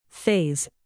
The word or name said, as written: faze